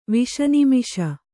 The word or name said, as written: ವಿಷನಿಮಿಷ